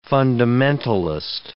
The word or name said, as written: fundamentalist